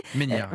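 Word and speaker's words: Ménière: On a entendu parler aussi de la maladie, alors je ne sais pas si je le dis bien, de Meunière, vous allez me corriger. – Meunière.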